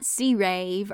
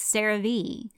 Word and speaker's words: CeraVe: It's not C-Rave or Survey, it's CeraVe.